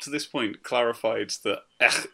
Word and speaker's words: Aech: Aech pronounced his own avatar's names just like the letter H. No, he did not. He pronounces it like Ech.